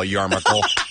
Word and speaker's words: yarmulke: Yarmulke. Is the pronunciation.